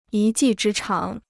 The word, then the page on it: 一技之长: yī jì zhī cháng